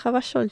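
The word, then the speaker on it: Revachol: Вони ж не просто приїхали в Ревашо як найманці, а вони для чогось якось. Ель читається. – французькому ель читається. Він гавашоль.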